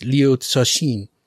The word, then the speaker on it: Liu Cixin: Cixin Liu, something like that, is what I would usually say. But I think, as I understand it, I looked up like how to pronounce it, and as far as I can tell, it's Liu Cixin.